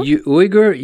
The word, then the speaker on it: Uygur: Is that how you say his name? – I think so. – Uygur, U-Y-G-U-R. Gent Uygur.